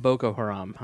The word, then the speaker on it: Boko Haram: We're not trying to belittle it, but Hoko Haram, am I saying that right? – Boko Haram, I'm sorry.